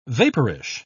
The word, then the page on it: vaporish: British English